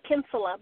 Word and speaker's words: Kinsella: It's actually Kinsella, but I'll answer to anything there.